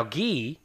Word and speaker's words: ghee: Now, ghee, pronounced with a hard G, is the Hindi word for fat.